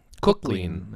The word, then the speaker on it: Cook-Clean: It's actually pronounced cook, as in book, hook, or Q-Clean. Chef. – Cook-Clean. That's his handle.